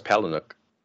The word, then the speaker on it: Palahniuk: And Chuck Palahniuk's purpose in... – Is that how you pronounce that? – I think so. – I thought it was Palahniuk. – Palahniuk. Yeah, I was close.